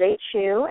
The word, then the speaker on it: HU: And it's spelled H-U, and it sounds exactly like that.